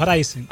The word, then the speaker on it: Ryzen: Racing, ¿eh? Racing. No, Ryzen. Ah, yo pensaba que era Racing. Así que Ryzen se escribe con Z, Ryzen e Y.